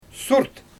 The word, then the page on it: surt: Xyzyl